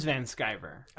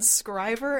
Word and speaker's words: Van Sciver: I've heard a few different pronunciations online, and I'm not completely sure, so we're going to go with Noah Van Sciver. – I think it is Van Sciver. – All right. – Yeah. – Okay. – I heard Scriver and Shriver.